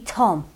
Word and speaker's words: Thom: And we've had another one off Thom, who turns out to be Tom. – Yep. – It's a silent H.